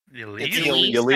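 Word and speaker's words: Ylisse: Is it Lyssa? How do you say it? – Yalise. I think is how they say it.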